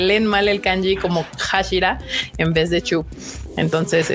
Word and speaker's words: Hashira: Entonces ellos se quedan con la idea de que así se pronuncia, o sea, que se lee como Hashira. Y de hecho hay dos, eh, bueno, uno en particular que es el de fuego, que se lee como en Bashira, como lo tienen aquí justamente.